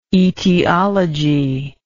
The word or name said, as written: aetiologist